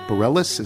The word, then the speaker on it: Bareilles: Emily Elbert is an ace guitarist who's played on stage with Jacob Collier, Broadway star Sarah Bareilles, is that how it's pronounced? – Yep.